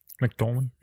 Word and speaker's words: McDormand: Men, øh, men Nomadland følger den her øh, kvinde, som har spillet af Frances Mac McDormand. Ja, det er sådan, det udtales, ja.